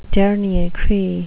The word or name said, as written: dernier cri